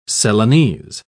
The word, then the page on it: celanese: British English